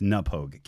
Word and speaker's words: Knappogue: Do you spell Pogue, P-O-A-G? – No, it's P-O-U-G-E. It's Napogue. Hang on. – Oh, okay. – Yeah, it's spelled K-N-A-P-P-O-G-U-E.